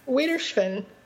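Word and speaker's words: Auf Wiedersehen: How do you say it? – Wiedersehen. – Okay. – I'm pretty sure that's how you say it, off Wiedersehen, which means goodbye until we can meet one another again.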